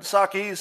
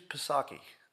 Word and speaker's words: Psaki: You could call her Jen Psaki if you like, but if you're going to put an unnecessary consonant in the front of your name, I think I can pronounce it. Don't tell me what's silent. Don't tell me to shut up about the silent P. I'm like, you know, Pierce Morgan. I want my freedom of speech. If I want to pronounce the P in front of Psaki, well, damn it, I'm going to do it.